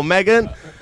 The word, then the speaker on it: Meg: Megan? – Yo, what's up? I'm Megan, but you can call me Meg. It rhymes with egg, but begins with the letter M.